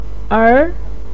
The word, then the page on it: er2: pinyin